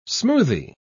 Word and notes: smoothy: British English

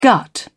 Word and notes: gut: British English

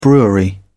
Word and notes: brewery: British English

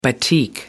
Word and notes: batik: American English

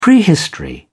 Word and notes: prehistory: British English

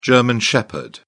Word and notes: german shepherd: British English

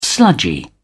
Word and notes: sludgy: British English

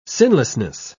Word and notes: sinlessness: British English

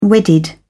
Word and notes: wedded: British English

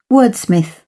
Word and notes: wordsmith: British English

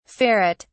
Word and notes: ferret(s): British English

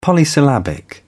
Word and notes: polysyllabic: British English

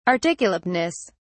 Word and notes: articulateness: British English

